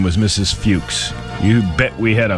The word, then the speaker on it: Fuchs: It's Fuchs. – I believe it's Fuchs. – That's what people who have that name pronounce it as.